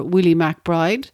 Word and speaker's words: MacBride: Bogle had previously stated that he had no single soldier in mind for Willie MacBride, but he was looking for a name to rhyme with graveside.